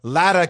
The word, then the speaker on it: Lada: I just think Jen Latta came on. See, I said it correctly, Pat Costello. Latta came on and broke down all their issues that they have as relates to their O-line, some other troubles.